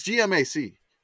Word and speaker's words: GMAC: Oh, by the way, I wanted to correct something when you said the GMAC. Mobile, Alabama. It's GMAC. You read all the letters.